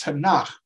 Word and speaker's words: Tanakh: No, the, the Hebrew acronym is pronounced Tanakh, which is standing for the T is for Torah, the five books of Moses.